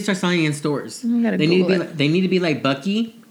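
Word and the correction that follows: Buc-ee's: or is that how you say it? Bucky? – Bucky's, which is coming to Missouri, by the way.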